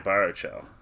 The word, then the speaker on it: Baruchel: About Jay Baruchel.